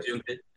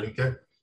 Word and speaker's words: Yunque: Yunque, yeah. – Yunque.